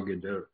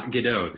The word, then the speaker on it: Gadot: So we will move on to our next story, which is about Joss Whedon and Gal Gadot. So the story Gadot. – She pronounces the T. I'm telling you, I heard her pronounce the T.